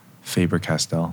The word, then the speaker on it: Faber-Castell: Like you don't really have artists that are sponsored by Faber-Castell.